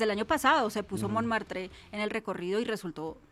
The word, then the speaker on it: Montmartre: Pero el domingo pasamos por Montmartre, perdón mi pronunciación del francés, ya sé que así no se pronuncia. – Montmartre.